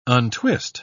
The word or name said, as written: untwist